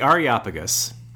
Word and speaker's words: Areopagus: So the Areopagus is... – The Areopagus, which I think I pronounced something a little slightly different than that.